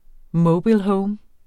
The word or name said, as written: mobilehome